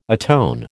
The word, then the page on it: atone: American English